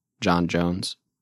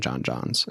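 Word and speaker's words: J'onn J'onzz: I do want to say that some of what I've been reading is that it's probably pronounced John Jones. And that's why he chose John Jones as his human name. In the last episode, I was saying John Johns, and so.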